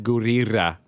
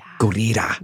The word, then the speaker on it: Gurira: Danai Gurira. Gurira. – Mm-hmm. – She taught me how to say it. She was like, double roll the R. Gurira. I still don't think I said it right, but...